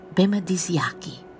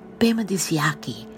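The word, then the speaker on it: Bemaadiziiaki: The Anishinaabe word for living beings in the earth is Bemadisiaki. Bemadisiaki.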